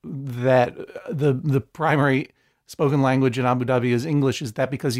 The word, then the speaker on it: Abu Dhabi: Zeba, you guys just, how long ago did you move to Abu Dhabi? Am I pronouncing it correctly? – Yeah. We moved to Abu Dhabi almost 5 years ago.